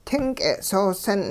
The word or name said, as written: ting k'e'soosi'n